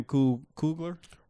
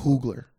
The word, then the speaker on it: Coogler: But yeah, I need Ryan Coogler. – Ryan Coogler to direct that. – Coogler. Bitch. He said it right the first time and then fucked it up the second time.